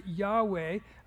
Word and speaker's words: Yahweh: which we're not even 100% sure how they would have pronounced it, but we pronounce it Yahweh, the name of God, but they wouldn't say the name of God.